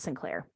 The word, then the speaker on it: St. Clair: like S-T period Clair, which is even more ridiculously over the top and pompous, right? But I think at the time in the 19th century, that name would have been pronounced Sinclair. I could be wrong. If I am, kindred spirits, please feel free to reach out and correct me on that. But I'm pretty sure that this one's pronounced Sinclair.